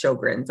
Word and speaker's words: Sjogren's: but it's pronounced Sjogren's, like TV show grins, G-R-I-N-S.